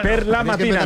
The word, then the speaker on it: per la mattina: Per la mattina, no por la mañana.